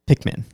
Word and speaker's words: Pikmin: you could play Pikmin. – Pikmin. – Not Pac-Man, but Pikmin. – Min. – M-I-N. How do you spell it? – I think it's P-I-K-M-I-N.